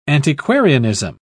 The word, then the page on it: antiquarianism: British English